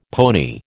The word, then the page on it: pony: American English